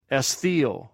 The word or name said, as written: esqiw